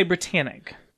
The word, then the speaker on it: Britannic: The first was the RMS Olympic, and the third was the HMHS Britannic. I believe that's how you say it. – Okay. – We're going to say Britannic.